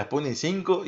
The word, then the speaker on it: Sputnik V: Hay que decirle a Morré cómo se dice realmente el nombre de la vacuna. bueno, el Sputnik V. No, no creo que Putin diga que se llame Sputnik 5, es la Sputnik 5 y en ruso cómo debe ser el nombre.